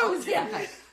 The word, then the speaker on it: Ozempic: Ozempic. – No, that's how you say it. – Say it again. – Ozempic. – Ozempic. – Ozempic. – It's an E, not an A.